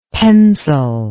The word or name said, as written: pensil